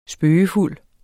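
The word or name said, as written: spøgefuld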